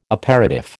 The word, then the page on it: aperitif: American English